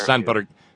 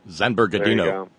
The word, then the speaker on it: San Bernardino: The problem with this camera, we saw Black Sabbath last Saturday night at something called Ozfest that's held out in San Bernardino every year. – No, that's San Bernardino. No, you have to pronounce it like Rafael Eduardo Cruz, the man who forgot to become an American for a very long time.